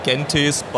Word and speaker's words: Gentis: So transitioning now over to what's coming next, and I know that, and forgive the pronunciation, is Gentis? – Yes, it's Latin, and in Germany we would say Gentis, but whatever.